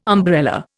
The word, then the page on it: umbrella: Indian